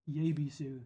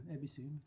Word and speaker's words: Yebisu: But we pronounce Yebisu, Ebisu, because that's how Japanese pronounce it.